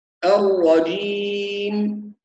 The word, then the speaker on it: الرجيم: الشيطان بهذه الطريقة، الرجيم هكذا ننطقها، الرجيم. طبعا الجيم بعض الناس ينطقها جي أو جيم أو يقول جي فيها حرف الدال، وهذه كلها أخطاء، فالجيم تخرج من وسط اللسان مع الحنك الأعلى.